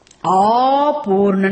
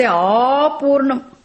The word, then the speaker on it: apurnam: Apurna, not apurna. Be careful here. In translating, apurna. You have to say apurnam.